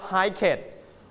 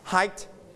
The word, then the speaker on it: hiked: So when we put ed, we don't pronounce bucket, we don't pronounce packet, and we don't pronounce hiket. We pronounce t. Okay. Everybody t. – T. – Booked. And volume down. Booked. – Packed. – Hiked.